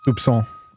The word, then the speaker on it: soupçon: Soupçon.